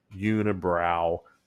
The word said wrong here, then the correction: Unibroue: uh, un- unibroue is uh, I believe that's the correct pr- correct pronunciation. Thank you, Quebec Quebecians, and uh, I promise I'm not having a stroke here.